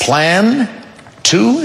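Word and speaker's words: Plan II: Plan... Two? Is it plan two? – Yes, that's how you say it.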